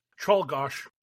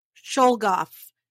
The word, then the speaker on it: Czolgosz: Czolgosz. – Czolgosz.